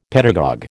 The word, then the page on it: pedagogue: American English